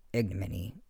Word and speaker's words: ignominy: It's actually pronounced ignominy, I think, and it means public shame or disgrace.